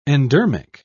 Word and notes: endermic: British English

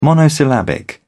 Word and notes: monosyllabic: British English

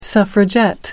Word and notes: suffragette: American English

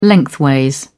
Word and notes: lengthways: British English

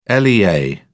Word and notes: lea: British English

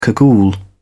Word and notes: cagoule: British English

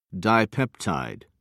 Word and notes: dipeptide: American English

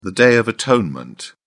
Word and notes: day of atonement: British English

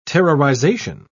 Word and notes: terrorization: British English

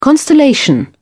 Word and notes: constellation: British English

